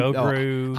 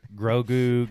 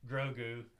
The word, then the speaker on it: Grogu: Said his name wrong all weekend. Like, me and Gogru. Oh, I couldn't say it right Gogurt, on the podcast last week. Yeah. Jacob had to keep correcting me. – Gogurt, Grogu, Gogru.